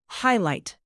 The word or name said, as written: highlight